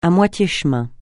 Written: à moitié chemin

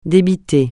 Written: débiter

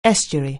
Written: estuary

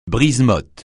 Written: brise-mottes

